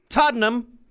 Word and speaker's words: Tottenham: She was Adele Laurie Blue Adkins, and she was born in Tottenham, London, England. – Yep. Good job. – That's how you pronounce those English Tottenham. – Names. You just say it as quick as you can. Tottenham.